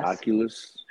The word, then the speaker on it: Oculus: Oculus.